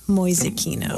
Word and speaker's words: Mäusekino: How do you spell that, Mäusekino? – M-A-U-S-E-K-I-N-O. Mäusekino.